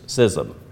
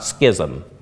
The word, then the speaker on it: schism: Yeah, it is pronounced like scissors, schism, not schism. Even though every newscaster on television says schism. It is schism.